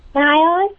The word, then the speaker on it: Niles: Niles. – I'm sorry, what? Can you spell it? – N-I-L-E-S.